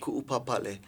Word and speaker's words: Ku'upapale: This is, it's K-U apostrophe U, and then P-A-P-A-L-E. – Ku'upapale.